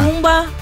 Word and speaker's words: Jacumba: Yocumba. – Jacuna. – Jacumba. – Sí, Jacumba Matata.